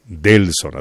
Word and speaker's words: Delson: Así es, sí, Delson, así se escribe mi nombre. D-E-L-S-O-N, Delson Dani.